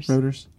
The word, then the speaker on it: Reuters: Reuters.